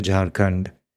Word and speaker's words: Jharkhand: Just to bring up one example of some of the phenomena you've just talked about, you go to Jharkhand, am I saying that correctly? To Jharkhand. – That's right.